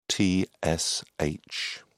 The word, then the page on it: tsh: British English